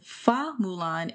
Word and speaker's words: Fa Mulan: Mulan's original name in the legend was Hua Mulan, which is the Mandarin pronunciation. Fa Mulan is the Cantonese pronunciation, which is what we get in the Disney in the animated version.